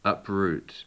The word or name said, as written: uproot